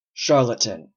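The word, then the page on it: charlatan: Canadian English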